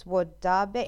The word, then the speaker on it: Wodaabe: It's Wodaabe. It's W-O-D-A-A-B-E. Wodaabe tribe? So sorry.